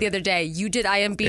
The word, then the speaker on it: IMDb: The IMDb description.